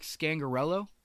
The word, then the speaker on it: Scangarello: Correct me if I'm saying this wrong. Rick Scangarello. – I think you said it right.